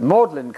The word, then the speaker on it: Magdalen: Magdalene is Magdalen College. My uncle was at Magdalen College, Oxford. Not Magdalene, please. No, no, no. Magdalen, and so on.